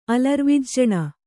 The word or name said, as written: ಅಲರ್ವಿಜ್ಜಣ